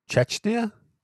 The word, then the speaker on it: Czechia: Czechia. – Chechnya? – Chechnya. No. – Is there an N in there? – No. Czechia. – Chechia. It's the Czech Republic.